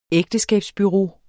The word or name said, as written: ægteskabsbureau